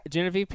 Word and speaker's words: Genevieve: Yeah, it's Genevieve. Yeah. – You jinxed me. You jinxed me is what you did. – I specifically said not to no Guinevere. – Yeah, no, it's all good. Genevieve Pearson from season one of King of the Nerds.